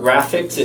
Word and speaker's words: GRAF1X: It's called Graphics. It's GRAF1X is how it's spelled.